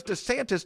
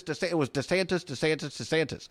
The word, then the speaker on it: DeSantis: DeSantis somehow suddenly now he can't decide how to pronounce his name. Because it was DeSantis, DeSantis. Well, now suddenly he's running for president in Iowa. He's gone back and forth between DeSantis and DeSantis.